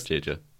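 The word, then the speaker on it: jæja: Oh, that's Jeja. – Oh, this one has the letter Ash. – Yeah, this is spelled J-A-E-J-A.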